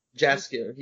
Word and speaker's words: Jaskier: Yeah, so I think his name is Jaskier, which is like, it's supposed to be a soft J, but I mean, the thing... – Jaskier, is it right? – Jaskier, yeah, Jasper.